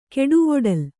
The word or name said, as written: ಕೆಡುವೊಡಲ್